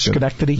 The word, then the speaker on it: Schenectady: WGFM, now WRVE, and how do you say that word in New York? – Schenectady.